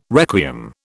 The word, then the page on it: requiem: British English